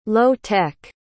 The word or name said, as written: low-tech